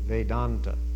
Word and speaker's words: Vedanta: This A is long. When the two A's come together, it becomes a long A, Vedanta.